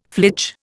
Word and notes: flitch: Indian

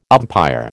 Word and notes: umpire: American English

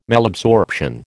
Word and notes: Malabsorption: American English

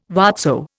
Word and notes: whatso: Indian

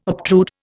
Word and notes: obtrude: Indian

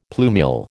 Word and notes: plumule: American English

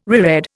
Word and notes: reread: Indian

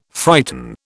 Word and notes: frighten: British English

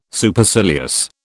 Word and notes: Supercilious: British English

